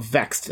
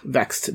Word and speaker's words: VXT: Going by their new tag team name of Vexed, that's spelled V-X-T, Vexed, they defeated the team of Mia Yim and Jordan Grace.